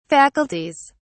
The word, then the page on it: faculties: British English